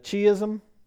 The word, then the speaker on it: chiasm: but a chiasm or a chiasm? Chiasm? – Yeah. – Chiasm. Thank you. See, I knew there was somebody here that would know how to pronounce it.